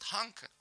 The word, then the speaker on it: Тханка: Так вот, и оттуда, с сегодняшней выставки, я решил принести сюда в эфир сюжет, который называется «Очищение белого слона», «Тханка» или, ну, там, вот это «Х», как он, предикативный, да, звук? – Фрикативный. – Фрикативный, нет, не знаю, он не читается, то есть это «Тханка», так правильно говорить, это икона фактически.